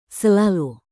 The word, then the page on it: selalu: indonesian